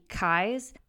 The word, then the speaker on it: Keyes: Abby's name is pronounced like eyes with a K, Abby Kies, but it's spelled like the word key, K-E-Y-E-S.